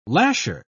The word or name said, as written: lasher